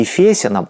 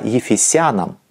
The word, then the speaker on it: Ефесянам: Кстати, насчет ударения здесь меня не поправляйте. Не Колоссянам, а Колоссянам. Русский орфографический словарь Российской академии наук под редакцией Лопатина. Также не Ефесянам, а Ефесянам. Ударение на суффикс в этих словах применялось и в старинных славянских текстах.